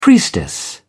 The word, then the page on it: priestess: British English